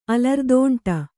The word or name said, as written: ಅಲರ್ದಂಡೆ